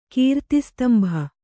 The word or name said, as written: ಕೀರ್ತಿಸ್ತಂಭ